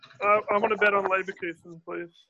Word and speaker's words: Leverkusen: How do you pronounce that again? – Leverkusen. Werder Bremen versus Leverkusen.